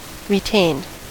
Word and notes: retained: American English

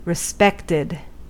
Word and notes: respected: American English

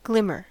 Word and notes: glimmer: American English

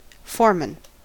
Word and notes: foreman: American English

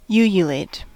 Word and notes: ululate: American English

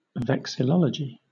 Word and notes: vexillology: British English

